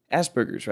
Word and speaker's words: Asperger's: Okay, that he has Asperger's, right? That's how you pronounce it. – Mm-hmm.